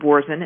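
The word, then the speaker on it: Boursin: So then you take this garlic and herb cream cheese, which is called Borzen, and if you can't find it, it's spelled Borsin.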